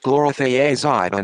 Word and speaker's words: chlorothiazide: Luis sample tested positive for the banned diuretics chlorothiazide and hydrochlorothiazide.